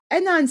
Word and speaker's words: Adnan: So Adnan Syed was convicted of how do you say his name? I think it's Adnan. Adnan. Adnan Syed was convicted of strangling. Adnan, right? Yes.